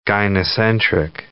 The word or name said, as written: gynocentric